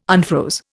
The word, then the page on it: unfroze: Indian